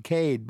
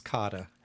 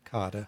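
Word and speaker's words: Kade: You know, and that first little introduction I did in the beginning, I pronounced it the Max Cade Institute, and I looked up the pronunciation, and it said most Americans pronounce it Cade, but it's not. – That's correct. His name is pronounced Max Kada. – Kada, and that's the German. – That's the German pronunciation, and that's the pronunciation that most of us who are affiliated with entities that have that name go by.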